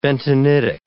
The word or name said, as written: bentonitic